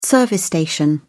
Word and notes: service station: British English

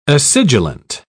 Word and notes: acidulent: British English

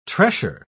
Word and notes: tressure: British English

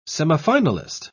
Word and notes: semifinalist: British English

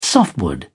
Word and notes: softwood: British English

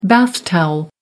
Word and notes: bath towel: American English

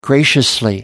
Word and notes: graciously: British English

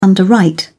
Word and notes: underwrite: British English

